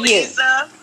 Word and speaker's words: Leaza: Lisa, L-E-A-Z-A, Dudleyo. You know, I've been called Lisa all my damn life. Even my aunties and uncles that have known me for years call me Lisa. And I have to correct them nowadays and let them know that it's spelled with a Z.